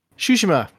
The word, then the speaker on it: Tsushima: Tsushima. – There you go. Whatever. There's a T in the fucking beginning, like tsunami. Anyway, yeah. Did you finish that? – Pronounce tsunami, but instead of tsunami, go Tsushima.